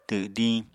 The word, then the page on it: twdiñ: apatani